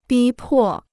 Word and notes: 逼迫: bī pò

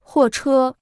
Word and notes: 货车: huò chē

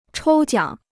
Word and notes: 抽奖: chōu jiǎng

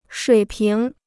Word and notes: 水平: shuǐ píng

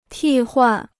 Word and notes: 替换: tì huàn

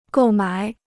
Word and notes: 购买: gòu mǎi

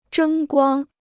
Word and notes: 争光: zhēng guāng

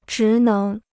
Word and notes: 职能: zhí néng

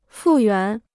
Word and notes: 复原: fù yuán

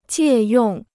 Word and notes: 借用: jiè yòng

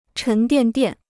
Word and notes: 沉甸甸: chén diàn diàn

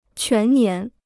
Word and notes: 全年: quán nián

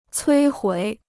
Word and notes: 摧毁: cuī huǐ

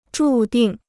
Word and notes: 注定: zhù dìng

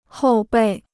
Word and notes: 后备: hòu bèi

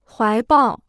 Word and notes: 怀抱: huái bào

